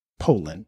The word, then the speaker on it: Pollan: The pronunciation of the author I mentioned is Michael Pollan, Poland.